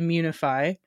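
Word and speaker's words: Immunefi: So today we're going to be talking to Mitchell Amador, who is the founder of Immunify, as we were just talking, that's how you're supposed to pronounce it, Immunify.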